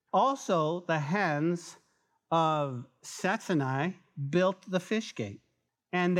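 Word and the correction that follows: Hassenaah: Hassaniah, I said that wrong first time, Hassaniah built the fish gate.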